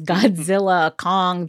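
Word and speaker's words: Godzilla x Kong: So first of all, I want to clarify, the studio says that the X in the title is silent. So the title is Godzilla Kong, the New Empire.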